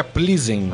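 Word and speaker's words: Plzeň: Aí nós teremos ainda também o Victoria Plizen é é? contra. – é, como é que é a pronúncia? Victoria Plizen. Ah, perfeitamente.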